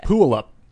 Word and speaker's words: Puyallup: Oh, and it's Puyallup.